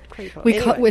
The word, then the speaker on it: Crapo: C-R-A-P-O. – Crapo. – But we call it Crapo. – It's pronounced Crapo.